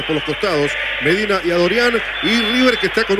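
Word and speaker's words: Adoryan: Yo dije a Dorian y me parece que se pronuncia así. – Es porque es de ascendencia armenia, el amigo. – Bueno, a Dorian entonces será.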